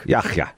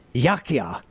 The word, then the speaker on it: Yahya: Yahya. Yeah, it's not a hard K sound. Yahya. It's like Yahya.